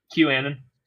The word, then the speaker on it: QAnon: How do you say it? – QAnon. – QAnon.